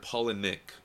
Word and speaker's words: Palahniuk: Yeah, I've always heard Paul and Nick, like Paula and Nick put together.